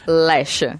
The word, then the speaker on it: Lexa: L-E-X-A. – X-A, exato. – E se pronuncia? – Lecha.